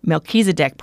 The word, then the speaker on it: Melchizedek: I saw on the website about the, and I'm going to try to pronounce this correctly. You coached me once. Melchizedek Project. – That's correct.